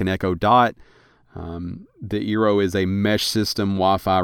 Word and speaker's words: Eero: Eero, I think is how it's pronounced. Eero 6 Eero.